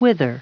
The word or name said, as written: wither